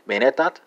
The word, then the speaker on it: menettät: That's going to be menettät, spelled M-E-N-E-T-A-T.